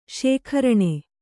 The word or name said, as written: ಶಿಖರಿಣಿ